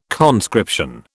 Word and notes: conscription: British English

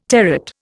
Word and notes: territ: Indian

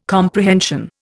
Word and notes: comprehension: Indian

